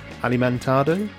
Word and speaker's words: Alimentado: Is it Dr. Alimentado? – Yeah.